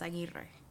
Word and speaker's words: Aguirre: Yes, but my maiden name is Aguirre.